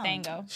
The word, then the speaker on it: Django: And he said the J is silent. – So it's no. Django. – Shut up. I messed up. – Game over, yeah! I messed up. It's the D is silent.